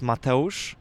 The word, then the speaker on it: Mateusz: So I'm Polish, so it's pronounced Mateusz, but in English, it's just Matt.